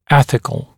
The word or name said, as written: ethical